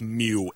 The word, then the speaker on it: Miu: I believe it's Mew, M-I-U. – Nick Mew